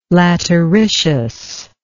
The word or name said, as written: lateritious